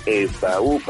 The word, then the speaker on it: Esaú: No, me llamo Esaú, Panda. – Esaú, o sea, con acento en la U. – En la U, ándale, exacto.